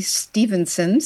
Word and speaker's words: Stevenson: Zue Stevenson, spelled with a V, Stevenson.